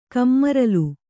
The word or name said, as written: ಕಮ್ಮರಲ್